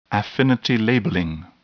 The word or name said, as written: affinity labeling